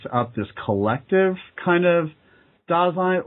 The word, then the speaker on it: Dasein: Just real quick, like what is going on with Dasein? What, like, tell me if my understanding of Dasein. – Dasein, yeah.